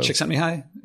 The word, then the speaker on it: Csikszentmihalyi: Csikszentmihalyi. – Yeah, there you go. You said it.